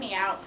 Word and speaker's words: Meowt: And by the way, the me out in Miss Check Me Out is spelled M-E-O-W-T. – It sounds like, please check me out.